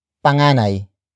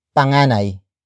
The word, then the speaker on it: panganay: You can say panganay. Panganay. Panganay. Panganay is spelled as P-A-N-G-A-N-A-Y.